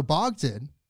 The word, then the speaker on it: Bogdan: I feel like it's a little bit easier than Dr. Bogdan, or if you say it in the traditional Ukrainian, it would be Bogdan.